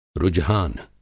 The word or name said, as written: रुझान